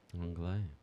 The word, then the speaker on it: anglais: How do you say English in French? – Anglais. – Is it English? – Anglais.